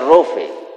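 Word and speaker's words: Rofe (רופא): Entonces, la palabra actualmente en nuestro lenguaje equivale a médico y se pronuncia Rofe.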